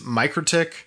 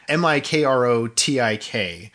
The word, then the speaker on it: MikroTik: So most of these 250,000 infected devices as part of this botnet all come from one router manufacturer, which I think is pronounced Mikrotik, Mikrotik.